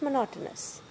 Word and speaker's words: monotonous: The break between the mountainous studies and everything. Okay, I'm talking absolute bullshit. – No, you just mispronounced monotonous. – Monotonous, yeah.